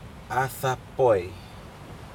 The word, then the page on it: adaPoi: Aneityum